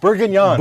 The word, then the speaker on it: Bourguignon: Bourguignon. – Bourguignon.